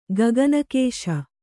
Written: ಗಗನಕೇಶ

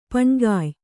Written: ಪಣ್ಕಾಯ್

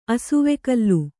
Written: ಅಸುವೆಕಲ್ಲು

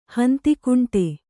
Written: ಹಂತಿಕುಂಟೆ

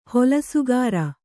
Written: ಹೊಲಸುಗಾರ

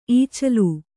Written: ಈಚಲ

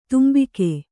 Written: ತಂಬಾಕು

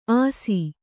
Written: ಅಸಿ

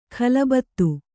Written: ಖಲಬತ್ತು

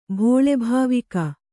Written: ಭೋಳೆಭಾವಿಕ

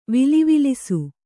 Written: ವಿಲಿವಿಲಿಸು

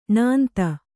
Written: ಣಾಂತ

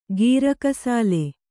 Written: ಗೀರಕಸಾಲೆ

